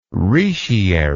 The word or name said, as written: retiary